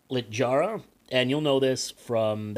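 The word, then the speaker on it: Littjara: Reflection of Lit-Jara. – Lit-Jara, it's a soft J.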